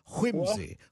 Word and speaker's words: whimsy: You got to put a hard "wh" on it, like whimsy, whimsy.